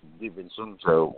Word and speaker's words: DiVincenzo: But I would think if you're getting Allen, you're getting somebody who's more of a scorer than DiVincenzo. I don't know how to say his name, but hey, you nailed it.